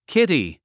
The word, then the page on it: kitty: kíti; キティ